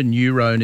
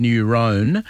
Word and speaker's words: neurone: Helen messaged in to say at 10 minutes past midday, Trent, sorry, but motor neurone is not said as neuron. I didn't say neuron, or if I did, I said it by accident, Helen. The professor actually pronounced it neuron. I've always said neurone, but when the professor said neuron, I thought, maybe I've been saying it wrong, but there you go.